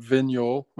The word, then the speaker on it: Vignoles: And then there's a wine called Vignol, which is spelled Vignoles, which is a hybrid.